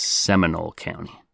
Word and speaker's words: Seminole: Specifically, we're going to Longwood, which is a city in Seminole County. Sorry, actually, I think that's pronounced Seminole County.